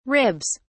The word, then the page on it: ribs: British English